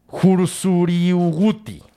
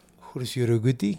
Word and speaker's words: Huzruiwuhti: Es decir, uno siguió siendo el sol, Tawa, y el otro se convirtió en, y está cabrón pronunciar esto, Riguti. – Bienvenido a mi mundo. H, es que, ve cómo se escribe, güey. H-U-Z-R-U-I-W-U-H-T-I. – Ok, entonces, ¿cómo se dice? – Jursuriuguti. – Jursuriuguti. Ajá. Jursuriuguti.